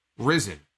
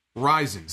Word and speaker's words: Ryzen: So now I'm being told that is the correct pronunciation. Ryzen.